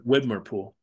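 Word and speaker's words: Widmerpool: Windermere Pool and Widmer Pool.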